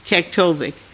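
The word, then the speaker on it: Kaktovik: Kuktuvik, for those of you that don't know where it is, first of all, you may be used to it being pronounced Kuktuvik. I try to say Kuktuvik because that's the Inupiaq pronunciation, and they get upset if I totally butcher it.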